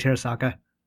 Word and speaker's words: Terasaka: The only person that can reach Nagisa is Terracotta. – Yeah. Terracotta throws his stun rod at him to get his attention. – You know, we've been pronouncing that wrong the entire time. It's actually Terasaka.